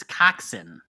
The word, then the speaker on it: coxswain: Now, I believe the actual pronunciation there is coxswain. Am I wrong on that? – Yeah, that's correct.